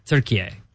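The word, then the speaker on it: Türkiye: They pronounce it Turkiye. – Turkiye. – It's pronounced Turkiye.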